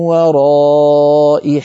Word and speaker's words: وراء: بعض الهمزات أيها الإخوة كما نرى على اللوحة رسمت همزتها ياء، كقوله تعالى في سورة الشورى من وراء حجاب، فالهمزة كتبت على هيئة ياء، فهذه الياء لا تنطق لا وصلا ولا وقفا. ففي الوصل نقول من وراء حجاب، فننطق همزة مكسورة، وإن وقفنا نقف من وراء بهمزة ساكنة،